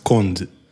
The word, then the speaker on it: Conde: So my last name in Portuguese is pronounced Conde. – Okay. – So I guess you would read it as Conde. So when I tell people, I say it's like Bond, but with a C.